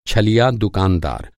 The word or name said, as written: छलिया